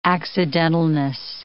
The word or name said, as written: accidentalness